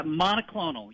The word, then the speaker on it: monoclonal: Mono monoclonal is that how you pronounce it? Yeah, monoclonal. Yes, sir.